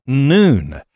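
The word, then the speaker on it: noon (ن): It's pronounced noon, when its fighters overran parts of Iraq.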